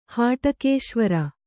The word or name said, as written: ಹಾಟಕೇಶ್ವರ